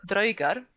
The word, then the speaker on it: draugar: Så att när du kommer där i din väldigt shiny och väldigt coola armor så får du möta drögar som är 3 meter mellan axlarna. Och det är ju inte jätteroligt kanske. Och ja, det uttalas dröjgar och dröjgur.